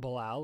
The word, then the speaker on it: Bilal: Bilal, like Bilal Powell, the former running back of the Jets.